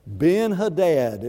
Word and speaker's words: Ben-Hadad: Ben-Hadad is the way we pronounce it.